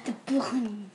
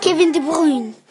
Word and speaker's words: De Bruyne: De Bruyne. – What was that? – Kevin De Bruyne. – Kevin De Bruyne. Say it into the microphone. – Kevin De Bruyne.